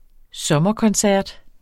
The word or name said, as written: sommerkoncert